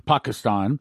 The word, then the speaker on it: Pakistan: He taught me how to pronounce it, Pakistan.